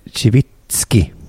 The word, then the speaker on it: Cibicki: Pavel Sibicki, uttalas det så? Han är MFF-are, va? Sibicki. – Det är faktiskt Civitski.